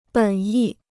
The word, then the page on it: 本意: běn yì